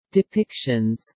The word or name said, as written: depictions